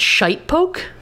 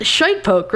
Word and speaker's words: Shitepoke: Mason County Sheriff George Johnson commented that he believed the sightings were due to an unusually large heron that he termed, I believe it's Shitepoke? S-H-I-T-E-P-O-K-E. – I mean, that's a name. – That first syllable is indeed shite.